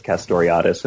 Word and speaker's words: Castoriadis: And here you're drawing on the work of the Greek scholar Cornelius Castoriadis, if I'm pronouncing it correctly. – Castoriadis, yeah.